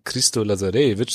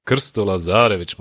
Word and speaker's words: Christo Lazarevic: Ja, also erstmal muss man es richtig raussprechen, nicht wie alle anderen, die immer sagen Christo Lazarevic, sondern Christo Lazarevic, muss man sagen.